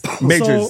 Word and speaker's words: Majors: So we're going to be talking about Jonathan Mayers. – Leave my guy alone, bro. – He's Jonathan Mayers? – Majors. So, Majors. – Yeah, Majors, whatever, however you pronounce his name.